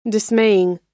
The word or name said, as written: dismaying